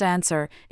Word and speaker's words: NSER: NSER, pronounced answer, is a 10-year industry-led plan for increasing U.S.